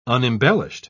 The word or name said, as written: unembellished